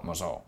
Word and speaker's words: Mosul: Mosul.